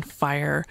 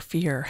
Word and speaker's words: Fyear: who are the poets that you hear on Fire or Fear, depending on how you pronounce it. F-Y-E-A-R.